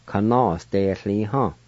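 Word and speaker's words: kanasdetliha: Ka-na-s-de-li-ha. Ka-na-s. De li ha. Kanos de li ha.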